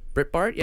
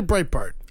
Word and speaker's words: Breitbart: Is that how you say it? Britbart? – Yeah. Britbart. I would say Breitbart.